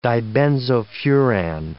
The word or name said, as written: dibenzofuran